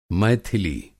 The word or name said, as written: मैथिली